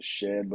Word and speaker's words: Shead: Did you see that dunk that Shed, I think you pronounce it Shed, but it's spelled like S-H-E-A-D, that putback dunk that he has? – That off the rebound?